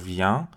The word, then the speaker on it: viens: توی سوم شخص جمع ایلوین الوین ولی توی صدا شخص اول اینجوری نیست کاملا از تو بینی تلفظ میکنیم جو viens تو ویان،